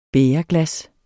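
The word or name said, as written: bægerglas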